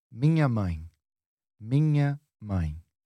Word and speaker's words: mãe: Yep, and then we have mãe, ai. – Ai. – Mãe, that's it.